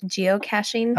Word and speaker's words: geocaching: Now, what I know there was, you introduced me to the idea of geocaching.